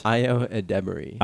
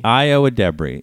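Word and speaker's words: Ayo Edebiri: Io Edebiri. – Io Edebiri.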